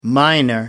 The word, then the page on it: miner: American English